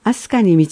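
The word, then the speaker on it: 飛鳥: 9 ページ、アスカ、飛ぶ鳥とアスカ、アス、香り。万葉歌に飛ぶ鳥のアスカと読まれており、飛ぶ鳥のはアスカに導く枕言葉として知られています。このため飛ぶ鳥もアスカと読むようになったようです。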